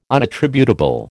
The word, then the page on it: unattributable: American English